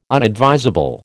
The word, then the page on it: unadvisable: American English